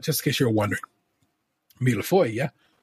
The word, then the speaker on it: millefeuille: In French, this would be pronounced millefeuille. Millefeuille. The S at the end of feuille is definitely silent.